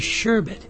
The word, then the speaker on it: sherbet: A lot of people say sherbet, but there's only one R in it.